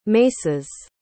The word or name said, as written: maces